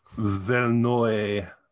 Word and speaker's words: Verneuil: So I, f- I found this very charming how to pronounce famous French film director names on YouTube, but um, Vernoy.